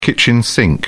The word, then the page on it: kitchen sink: British English